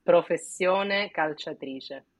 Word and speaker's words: professione calciatrice: It's professione calciatrice.